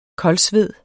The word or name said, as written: koldsved